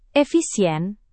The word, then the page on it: efisien: indonesian